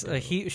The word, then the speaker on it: Gadot: Yeah, it's Gadot. She's Hebrew, or she's Israeli. But I listened to a Hebrew interview, and they're like, Gal Gadot.